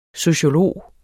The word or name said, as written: sociolog